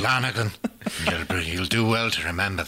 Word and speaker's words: Lonergan: And it's Lonergan. yeah, that's right.